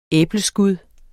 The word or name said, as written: æbleskud